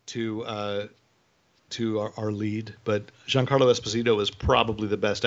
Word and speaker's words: Giancarlo: Now, and you're going to have to remind me the pronunciation of his first name, Giacascio Esposito. – Giancarlo Esposito.